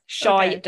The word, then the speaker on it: Shaeeda: It's, like shy, like oh, I don't talk shy. much. I'm shy. Oh, shy da. Got it. Shaeeda, okay.